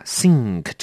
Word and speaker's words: sink: 好，大家要小心一下 sink 这个字。第一个提醒您它的发音，它是 S-I-N-K，在 K 或者是在 G 前面的 N 我们要念的比较重一点，所以这个字要念成 sink sink，有没有啊？鼻子要多用点力哦， sink，这样子就对了。